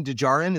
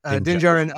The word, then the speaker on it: Djarin: And it's just I think it's amazing how well they crafted this this combination between Mandalorian Din Djarin, is that like, it's like a DJ kind of I think the second D is silent. It's like Django.